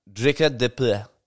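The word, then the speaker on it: Dricus du Plessis: Drikus du Plessis. That's not how you say it. It's like Drikus du Plessis.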